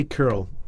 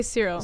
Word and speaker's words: Kirill: People say both. – People say Kirill. – In French, we say Cyril.